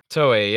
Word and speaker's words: Toei: Yes, because they were working with, and I'm going to butcher this, Toei. – Toei, yeah.